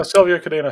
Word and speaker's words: Sylvia Cadena: And also a new CDO, Chief Development Officer, Sylvia Cadena. I'm probably pronouncing her name wrong, unfortunately. – Thanks. No, Sylvia Cadena sounds about right.